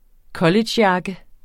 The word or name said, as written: collegejakke